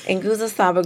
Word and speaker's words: Nguzo Saba: Nguzo Saba group.